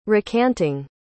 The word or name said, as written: recanting